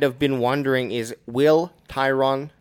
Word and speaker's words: Tyron: So Tyron Smith, kind of if like Byron Leftwich, that's how you say it.